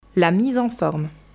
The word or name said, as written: La mise en forme